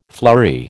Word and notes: flurry: American English

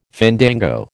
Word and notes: fandango: American English

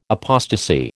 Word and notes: apostasy: American English

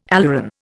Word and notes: aleuron: Indian

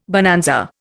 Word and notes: bonanza: Indian

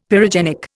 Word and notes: pyrogenic: Indian

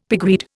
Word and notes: pigweed: Indian

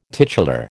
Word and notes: titular: American English

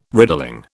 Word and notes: riddling: British English

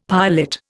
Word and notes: piolet: Indian

